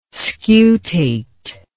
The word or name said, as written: scutate